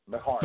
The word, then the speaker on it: Beharie: Number 12 on the list, Nicole Beharie. Is that how you say it? – Bahari. – There you go. Bahari.